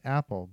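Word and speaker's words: Appell: So they found a guy named Dave Appel, who was actually, his name is pronounced Apple, but, you know, it sounds like Appel. But yeah, Dave Apple was essentially, he was a very invaluable addition to staff.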